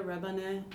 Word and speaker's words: Rebane: Yeah, Kaya Rebina, and the last name spelled R-E-B-A-N-E.